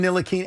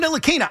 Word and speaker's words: Ntilikina: Finally, after all these years, Mike Breen's calling him Ntilikina. Everybody's calling him Ntilikina. I'm like, but Frank called him something else. And then I heard him, Frank, call himself Ntilikina.